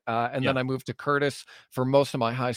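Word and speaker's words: Courtice: But then it goes like Pickering, Ajax, Oshawa, Curtis, if you will. – That's right. – And Curtis isn't spelled like Curtis's name. It's like Cortice. It's the most fucked up way to spell Curtis of all time.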